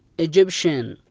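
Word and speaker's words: Egyptian: الكلمة الثانية تي اي اي تنطق التي هنا بحرف شا، مثال عليها ايجيبشن، ايجيبشن وهي معناها مصري الجنسية.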